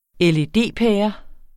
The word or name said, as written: LED-pære